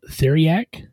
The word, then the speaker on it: Theriac: But the last one that they, as far as potion-wise, that they talked about was, and I may butcher this name, Theriac. – Sounds correct to me.